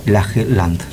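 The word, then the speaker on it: Lage Lund: Se escribe Laje Lund, se pronuncia así como casi como suena, Laje Land.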